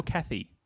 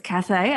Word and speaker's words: Cathay: I said Cathay Pacific, and that's just out of habit, I guess, because Cathay, is it Cathay or Cathy? – Well, it's Cathay as it's spelt. – Oh, sorry. So it's Cathay as in C-A-T-H-A-Y, Cathay Pacific.